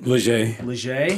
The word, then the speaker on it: Legier: Did I say it right? – Legier. Close. – I was close.